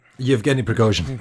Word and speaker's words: Yevgeny Prigozhin: Yevgeny Prigozhin.